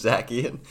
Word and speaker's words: Zacian: How do you say it? – I think it's Zacian. – Yeah, that's Zacanian. Yes. You added like four extra letters. – Yep, you're right. Zacian.